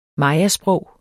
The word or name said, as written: mayasprog